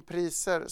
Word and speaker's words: Pricer: Men Ulf, Christian pratar ju om priser som centralt här. Du har skrivit en aktierekommendation om ett bolag som heter Priser, fast stavat med C och uttalat Pricer.